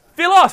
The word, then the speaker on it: philos: Philos. – That's what I thought. Philos. So this is about mateship. So before, when you guys were talking about the love for the boys, right? This is Philos.